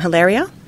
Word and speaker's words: Hilaria: His wife, Hilaria, how do we pronounce her name? Hilaria? – Yeah.